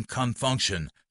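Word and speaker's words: Con Funk Shun: Project Soul became Confunction. That's a three-syllable hyphenated spelling. CONFUNK-SHUN.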